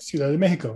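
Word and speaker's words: Ciudad de México: The Mexico City Capitanes, the Capitanes de Ciudad Mexico. Did I say that right? – Ciudad de Mexico, very good.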